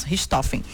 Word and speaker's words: Richthofen: Como é que se pronuncia aí? Suzane von Richthofen. Richthofen.